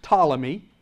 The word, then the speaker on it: Ptolemy: And the first one was Ptolemy with a P. With a P. It begins with a P, but it's pronounced Ptolemy.